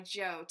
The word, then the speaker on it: Jo: It's one syllable. I always say Jo without an E.